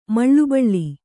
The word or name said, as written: ಮೌಲಬಲ